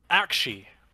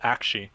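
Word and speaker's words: Aqshy: We're like, you have Gairan, the city of life, and then you have Akshi, which is actually how you say that. I looked it up. So, you know, Akshy, that is how you say it, Akshi.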